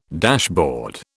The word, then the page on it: dashboard: British English